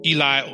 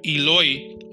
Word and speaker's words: Eloi: Eli or Eloi, which is spelled E-L-O-I.